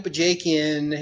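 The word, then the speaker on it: Bajakian: Because there's a lot of experience in Bajakin or Bajakin or however you pronounce it. I might pronounce it. – Oh, Bajakin. Right. Yeah, yeah. – Bajakin. – Yeah. – See, not even close.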